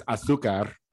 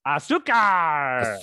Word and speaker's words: azúcar: But in Spanish, it's azúcar. – Azúcar. – Azúcar. – Azúcar. – You got to roll your R's though.